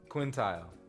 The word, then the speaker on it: quintile: Bottom quintal. – I don't think that word's quintal. – Quintile. – Quintile? Percentile? Is there a P? – With a T at the end. – Quintile. That's how you say it.